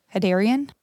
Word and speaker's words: Hadarian: Hadarian, H-A-D-A-R-I-A-N, Hadarian.